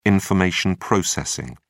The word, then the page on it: information processing: British English